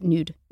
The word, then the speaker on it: Nuud: N-U-U-D. – Is that how we're saying it? – I believe so. It's Dutch. I don't understand Dutch pronunciation rules, but I think in the United States we pronounce it Nude.